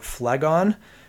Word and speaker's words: Phlegon: how do you pronounce this? It's a little bit hard, but Phlegon, it seems to be his name and how you pronounce it. Phlegon